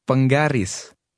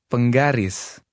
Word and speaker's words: penggaris: The stress is on the end of the word. – Penggaris. Penggaris.